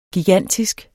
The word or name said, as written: gigantisk